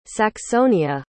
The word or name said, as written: saxonia